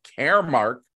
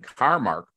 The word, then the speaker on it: CarMarc: And once he passed the state exam, Carrie, who is the managing broker of Caremark Realty, I got it right, because when you see it's spelled C-A-R-M-A-R-C, and everybody wants to call it Carmark, but they're wrong, absolutely wrong, as she was quick to point out to me right before we start.